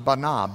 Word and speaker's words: banah: That word build in Hebrew is a word that's pronounced bana, B-A-N-A-H.